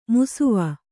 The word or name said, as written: ಮುಸುವ